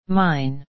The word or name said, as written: mine